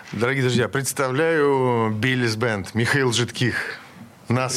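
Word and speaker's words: Billy's Band: Я что, правильно говорю? – Да, Биллис Бенд э, здесь.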